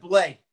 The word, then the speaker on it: Blais: Blias is very underrated. He was a very underrated. – I think we pronounced that Blais.